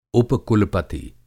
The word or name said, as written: उप-कुलपति